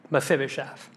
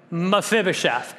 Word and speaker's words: Mephibosheth: Can you imagine signing your signature, Mephibosheth. Let's all say it together, Mephibosheth. If you think about four syllables, you can see it here on the screen, Mephibosheth.